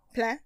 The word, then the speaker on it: Plein: Isn't Pline not pronounced like that, but spelled the same way? Rain in French? Plein? – Plein? Yes, it is. With the nasal sound on the end?